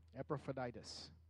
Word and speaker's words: Epaphroditus: Epaphroditus.